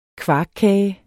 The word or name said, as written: kvarkkage